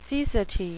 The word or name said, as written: cecity